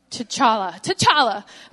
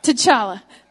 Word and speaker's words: T'Challa: T'Challa. I got it. T'Challa.